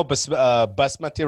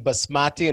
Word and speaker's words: basmati: But I made the mistake one time of saying basmati in front of my Pakistani friend, bro. Oh, my God. – How do they pronounce it? – Basmati. – Oh, basmati. – They'll be like, no, basmati.